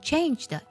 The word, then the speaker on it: changed: cannot be changed，在这里呢 changed 要注意它的尾音特定呢也要轻轻地读出来，不要读作 change 的啊，一定要不要读太重了。